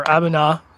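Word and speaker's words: A'bunadh: This is the Aberlura Bunda. Or Abuna, sorry, terrible, terrible Gaelic pronunciation there.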